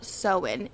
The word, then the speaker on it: Samhain: It's called Samhain, and when you look at the spelling, it does not look like Samhain. It looks like shaman, but it's that good old Celtic pronunciation. If my mom had named me Siobhan, my name would have been spelled S-I-O-B-A-H-N.